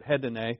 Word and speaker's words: hedone: Pleasures is the Greek word hedone. Uh, head. Uh, I think the accent's on the last syllable, but head, yeah, that would be it, hedone.